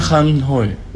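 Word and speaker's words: Khánh Hội: Nguyen Khan Hoi. Le KH, il faut prononcer comme arabe Khan. – Khan. – Pas canton ni canette. D'accord ?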